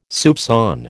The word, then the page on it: soupçon: American English